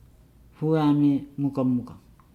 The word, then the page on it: mugamuga: Nukuoro